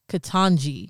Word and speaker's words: Ketanji: Ketanji.